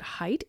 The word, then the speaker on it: Haidt: I think you pronounce it Haidt, H-A-I-D-T.